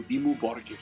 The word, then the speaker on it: Dimmu Borgir: En varias lenguas nórdicas se pronuncia algo como Dimmu Borgir.